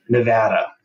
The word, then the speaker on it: Nevada: You gotta say Nevada. You can't come to Nevada and say it wrong. – So how do you say it? – Nevada. – Okay. It's Nevada.